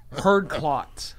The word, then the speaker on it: Herd Klotz: Herd Klotz. It's got to be named after somebody. It's spelled H-E-R-D K-L-O-T-Z.